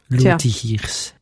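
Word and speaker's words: Lotigiers: Ihr Geburtsname ist Helmut Barthold Johannes Alma Lottigiers. – Ich hätte jetzt das auf Französisch ausgesprochen. – Kommt vom französischen Lottigier.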